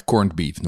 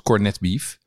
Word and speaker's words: corned beef: Uh, of eigenlijk corned beef noemen. – Ja. In Nederland wordt dat vaak uitgesproken als cornet beef.